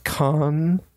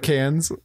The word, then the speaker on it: Cannes: Mine is, it's actually pronounced con. – Wait, what is this? – The Cannes Film Festival. Oh. Instead of Cannes. – It's spelled C-A-N-N-E-S.